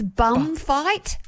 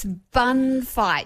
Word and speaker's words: bun fight: But it's been a bit of a bum fight. – Excuse me? A what? – That's what I used to say. I thought it was bum fight, right. But it's bun fight.